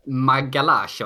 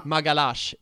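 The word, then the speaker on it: Magalhães: Det skulle uttalas Magalash, va? – Magalash, det stämmer. Magalash. Det ska vara ett sånt portugisiskt uttal.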